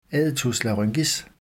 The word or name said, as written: aditus laryngis